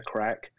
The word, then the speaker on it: craic: They call it the crack of a bar. And the crack, as they refer to it, is spelled C-R-A-I-C.